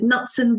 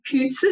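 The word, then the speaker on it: Knutson: I always pronounce it Knudsen, but it's actually Knudsen.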